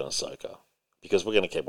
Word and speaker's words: Ahsoka: Ahsoka Tano. – Ahsoka.